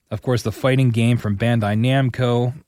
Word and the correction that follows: Namco: Why did I say it like that? Namco.